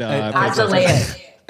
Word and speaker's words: azelaic: It's better with azelaic. – Azelaic. – Azelaic. – Emphasis on the ass.